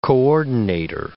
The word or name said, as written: coordinator